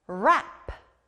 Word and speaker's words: wrap: 이 다음 단어도 R 소리가 나요. 바로 포장하다, wrap이에요. R 소리 들렸나요? 우리가 누군가에게 선물을 줄 때 예쁘게 포장해서 주죠? 포장하다, 영어로 wrap. wrap.